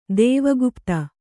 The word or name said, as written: ದೇವಗುಪ್ತ